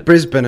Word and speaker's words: Brisbane: It's Brisbane and Melbourne. You know, that's how I would say Brisbane and Melbourne. That's kind of what I did, right? I think I did it right. That's how you say it in Australia, Fallout.